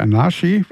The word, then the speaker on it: Anarchy: John the Revelator heißt das Ding und ist aus der TV-Serie Sons of Anarchy, oder Anarchy, wie es ausgesprochen wird, ist aus dem Jahre 2011 und ist auch hängen geblieben, ich glaube, beim männlichen Vornamen.